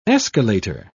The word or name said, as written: escalator